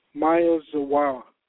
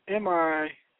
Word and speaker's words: Miyazawa: There's an individual by the name of Kenji, I can never say his name, it's Miyazawa. That's Miyazawa.